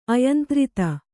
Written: ಅಯಂತ್ರಿತ

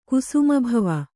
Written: ಕುಸುಮಭವ

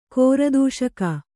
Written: ಕೋರದೂಷಕ

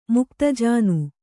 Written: ಮುಕ್ತಜಾನು

